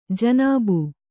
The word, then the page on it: ಜನಾಬ್: janāb